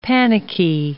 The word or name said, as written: panicky